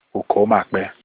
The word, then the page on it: uko makpe: Olukumi